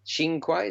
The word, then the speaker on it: Cinque: In late 1997, Anu Singh, beautiful law student in Canberra, killed her boyfriend, Joe Chinque. Chinque, is that how one pronounced? – Chinque. – C-I-N-Q-U-E.